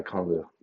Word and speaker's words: Okongwu: And then Okungun, whatever. Whoever they just drafted. What's his name? Onyeka Okongwu.